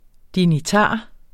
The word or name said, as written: dignitar